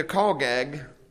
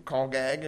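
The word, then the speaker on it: chagag: How are you planning to call gag the feast? I'll spell that for you. You might like it for your notes. It's a Hebrew word, and it's C-H-A-G-A-G. C-H-A-G-A-G, and it's pronounced call gag.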